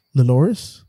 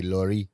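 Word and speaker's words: Lloris: It's again Loris. And Lori. – I think that is it, Luis. – You don't pronounce, they don't pronounce S's in names in France, so it'd be Laurie.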